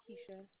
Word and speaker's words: Keisha: Keisha. – Keisha, K-E-I-S-H-A? – Yes.